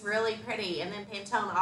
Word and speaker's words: Pantone: Like I love to look at what the new color trends are and Pantone. Is that how you say it? – Yeah.